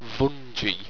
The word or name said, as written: vunjhe